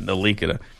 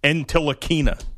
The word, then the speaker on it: Ntilikina: Yeah, Ntilikina. It's funny that people butcher that name so bad. I was listening to WFAN yesterday. Two guys just blatantly butchering the name, not even trying to get it right, using the same mispronunciation with it. – Both are identical? – Yeah. Entilikina. That's what they were saying.